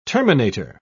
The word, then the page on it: terminator: British English